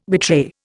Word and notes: betray: Indian

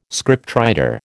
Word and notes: scriptwriter: American English

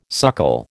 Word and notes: suckle: American English

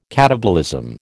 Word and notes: katabolism: American English

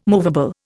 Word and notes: movable: Indian

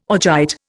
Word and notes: augite: Indian

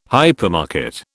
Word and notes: hypermarket: British English

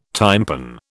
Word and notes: tympan: British English

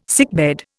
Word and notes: sickbed: Indian

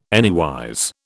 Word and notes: anywise: British English

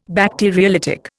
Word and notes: Bacteriolytic: Indian

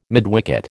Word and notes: midwicket: American English

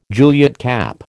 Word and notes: Juliet cap: American English

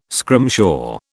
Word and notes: scrimshaw: British English

